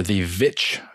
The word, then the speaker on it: The Witch: So this one is directed by Robert Eggers of The Witch or The Vitch fame, depending on how one pronounces it.